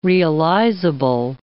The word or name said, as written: realizable